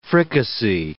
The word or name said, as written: fricassee